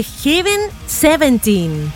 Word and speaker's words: Heaven 17: Y a nada más y menos que Heaven 17.